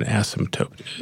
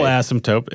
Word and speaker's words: asymptote: This is, I had to Google up what an asymptote is. Am I pronouncing it correctly? – You are.